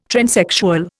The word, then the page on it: transsexual: Indian